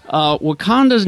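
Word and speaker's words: Wah-Kon-Tah: In Osage, the word is typically written as W-A-H, period K-O-N, period T-A-H, although it is pronounced Wakanda.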